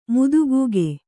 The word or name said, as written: ಮುದಿಕಾಗೆ